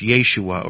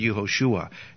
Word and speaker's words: Yeshua: And in his day, it was probably pronounced Yeshua or Yeshua. And you know what? He could have been called all three by different people. Some people might have said Yeshua, some might have said Yeshua, and some might have said Yehoshua. And